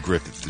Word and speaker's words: Gruffudd: Ian, I-O-N, Griffith, G-R-U-F-F-U-D-D. It's a Welsh name. – Wow. – Yeah, and Griffith is really how it's pronounced.